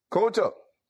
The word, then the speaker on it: Kyoto: Kyoto.